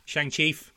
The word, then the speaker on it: Shang-Chi: Shang-Chi, I believe, is the best way to say it. – Shang-Chi, is that how it's pronounced? – Yes.